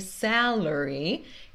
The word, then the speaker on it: salary: Não é salary, é salary is good, but he has no benefits. Então, salary, benefits. Essas duas sílabas meio fracas têm o som do schwa. Sa é a sílaba tônica.